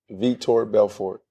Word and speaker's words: Vitor Belfort: Vitor Belfort. – How you say it? – Vitor Belfort.